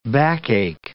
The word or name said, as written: backache